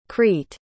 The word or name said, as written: crete